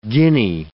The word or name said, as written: guinea